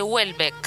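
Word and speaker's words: Houellebecq: Claro, la semana que viene vamos a estar leyendo Sumisión de Welbeck. – Se pronuncia así porque lo googleamos.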